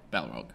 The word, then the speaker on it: Balrog: Balrog? – Balrog.